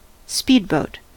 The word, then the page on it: speedboat: American English